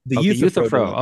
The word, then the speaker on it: Euthyphro: You talk about the Euthyphro dilemma. – Euthyphro.